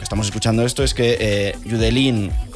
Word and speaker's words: Judeline: Pues entonces Judelín. Judelín. – Judelín. – Si es de Barbate, Judelín.